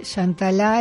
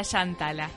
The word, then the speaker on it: Shantala: Shantala es una mujer hindú que se pronuncia con acento en la última. – Claro, yo decía Shantala. – Sí, la mayoría, pero conocí una mujer hindú que me enseñó que ese nombre se pronuncia con el acento en la última A,